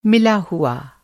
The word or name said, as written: मिला हुआ